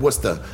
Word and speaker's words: Worcester: Honestly, that's how you pronounce it? – Worcester? – Yeah, but it's like Worcester. – What about the sauce? – It's Worcester. – Yeah, we have. What about the sauce? – Worcestershire. – Yeah, it's Worcester. Bad. But if you say it how it's spelled, it's a shit show.